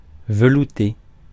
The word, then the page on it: soup: British English; /ˈsuːp/; American English; [ˈsuːp]; /sup/